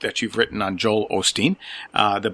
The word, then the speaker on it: Osteen: Yeah, well, I might have a little bit of that Texas drawl because I said Joel Osteen and you said Joel Osteen. I'm not sure which way it's pronounced. I guess that's my Wisconsin accent coming through and calling him Osteen. But I'll try to remember to call him Joel Osteen. Is that the typical pronunciation? Is that how he calls himself? – Yeah, Osteen.